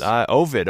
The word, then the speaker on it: Ovid: Coming in from Ovid. Is that how you pronounce that too? – Yes. – Ovid, okay.